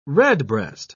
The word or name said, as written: redbreast